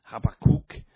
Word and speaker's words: Habakkuk: You might have heard it different ways. I think the correct way is more like Habakkuk. So maybe Habakkuk might be the best way to say it.